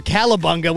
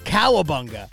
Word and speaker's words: Cowabunga: Wait, so we got all the votes for Calabunga. We forgot to tell them that it's actually pronounced Cowabunga.